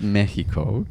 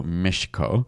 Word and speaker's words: Mexico: So it was closer to the sound sh. So Mexico is like how it would have been pronounced.